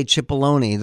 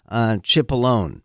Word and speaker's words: Cipollone: Actually, I think the best line that was used by the Trump defenders, by Pat Cipollone, and he does say Cipollone, though Adam Schiff keeps calling him Cipollone. And but I mean, I heard the man pronounce his own name, and yes, he uses the E and sounds it.